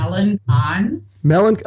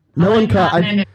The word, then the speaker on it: Melancon: So he just dropped the, in French, the cedille to make it son. Mm-hmm. Yep. And he just made up his own pronunciation. So it's Melancon.